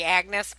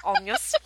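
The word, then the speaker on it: Agnès: It is the Agnes Varda documentary where Agnes, I would say, if I looked at her name, I'd say Agnes, but apparently it's pronounced Agnes.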